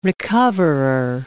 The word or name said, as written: recoverer